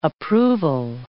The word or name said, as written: approval